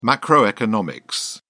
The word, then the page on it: macroeconomic: British English